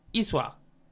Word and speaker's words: Issoire: porque hoy partirán de Vulcanía y terminarán en Iswa.